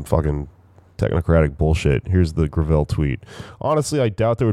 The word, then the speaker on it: Gravel: The hashtag that they came up with is I thought it was Gravel Gang, but apparently it's pronounced Gravel.